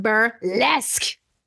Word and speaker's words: Burlesque: It's pronounced Burlesque. And it does. It screams lesque.